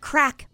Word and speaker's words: craic: You could say, do you know what crack means? Mm. These are Irish. – Make sure it's not an undercover cop there. – That's true. Be careful. Nobody? – No. – Crack. It's spelled CRAIC, and it is a good time, and it's used all the time.